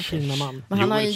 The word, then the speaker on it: Kinnaman: Hur uttalar man det? Kinnaman eller Kinnaman? – Jag säger Kin Kinnaman. Kinnaman ja.